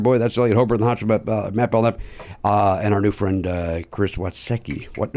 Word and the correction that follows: Witaske: Wait, I said it right. Watsecki. – You didn't that time. – No, I did not. – Well, if you're from Chicago, you say Witaske. – Witaske, that's it. Yeah. Like Pulaski.